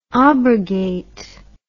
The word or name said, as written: obrogate